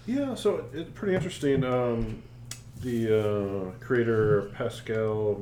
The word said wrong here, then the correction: Pasquale: Marco Veltris, sorry if I mispronounced that. – Pasquale, yeah.